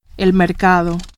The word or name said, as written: mercado